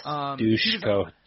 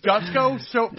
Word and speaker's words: Dusko: Yes, Dushko. Dushko. Yeah. – That's how you say it. I thought it was Dusko for so long.